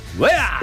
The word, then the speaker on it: Weah: Weah. – Weah.